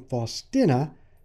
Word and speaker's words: Faustina: Now, I pronounce her name Faustina because a former student of mine, Jed Mialowicz, God rest his soul, politely reprimanded me for pronouncing her name incorrectly.